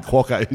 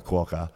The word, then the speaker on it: Quokka: It rhymes with Docker. – Yeah. So thank you, Dan. It does rhyme with Fokker. – If you didn't know. – I mean, Docker. – And that. – Docker the Fokker Quokka.